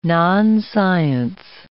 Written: nonscience

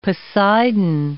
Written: Poseidon

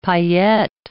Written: paillette

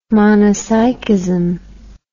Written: monopsychism

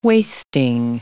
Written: wasting

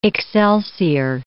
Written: excelsior

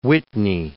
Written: Whitney

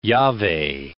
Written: Jahveh

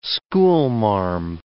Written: schoolmarm

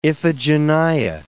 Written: Iphigenia

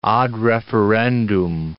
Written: ad referendum

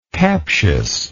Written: captiousness

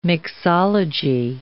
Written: mixology

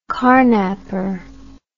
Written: carnapper

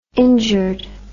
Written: injured

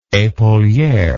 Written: epauliere

